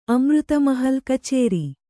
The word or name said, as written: ಖಚರ